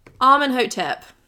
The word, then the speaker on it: Amenhotep: Amenhotep.